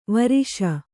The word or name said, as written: ವರ್ಷ